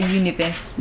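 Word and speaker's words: Tout l'univers: Can you pronounce it better than I can? – Tout le Univers.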